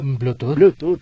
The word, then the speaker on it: Bluetooth: Bluetooth.